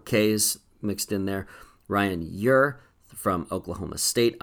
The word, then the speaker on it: Ure: I'm told on good authority that's how you pronounce this guy's name, spelled U-R-E, and told to me by one of his teammates. It's like Your, Y-O-U-R, or something like that.